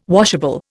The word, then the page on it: washable: Indian